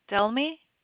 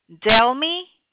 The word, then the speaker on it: Delmy: Delmi. – What is your name? – Delmi. – Can you spell that for me? – D-E-L-M-Y.